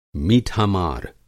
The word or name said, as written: मीठा-मार